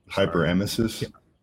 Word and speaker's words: hyperemesis: How do you say it? – Hyperemesis.